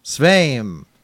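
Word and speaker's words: Sveum: Sveim.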